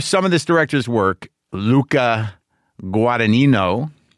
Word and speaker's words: Luca Guadagnino: Luca Guadagnino. – I did it. It's Brendan. Brendan's sending me the pronunciation. Luca Guadagnino.